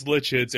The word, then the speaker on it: Litchens: It is Litchens. It rhymes with kitchens. If you have it, people mess it up. It's one of those pesky German names that I think it's just too easy. So I hear Lickens, Lichens, Leechens. Believe me, my elementary school years have a variety of ways of pronouncing that last name that you wouldn't believe.